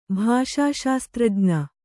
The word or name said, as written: ಭಾಷಾಶಾಸ್ತ್ರಜ್ಞ